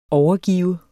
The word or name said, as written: overgive